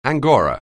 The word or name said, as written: angora